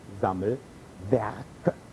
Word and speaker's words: Sammelwerke: Ja, und dann gibt es noch die sogenannten Sammelwerke, wobei die Betonung auf dem Wort Werke liegt.